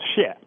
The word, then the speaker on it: Shea: It's pretty close, Shea. – Shea, okay. – Yes. – Sort of one syllable. Just get it right in there. It's spelled H-S-I-E-H for listeners who are right now going to Amazon and lining up a copy, which I hope many of them are.